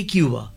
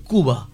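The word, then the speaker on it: Cuba: যেমন কিউবা, আমরা বলি থাকি কিউবা, কিন্তু আসল উচ্চারণ কুবা, কিন্তু উচ্চারণটা না অনেক বেশি লজিক্যাল স্প্যানিশে।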